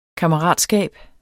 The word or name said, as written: KAMMERATSKABER